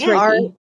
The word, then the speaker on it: Reiki: It is Reiki, right? I'm saying it right. – Yes, yes, it's Reiki.